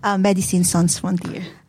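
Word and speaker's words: Médecins Sans Frontières: Paano ba pronounce tong M? – Medicine Sans Frontier. – Ayan, kasi nga French, no?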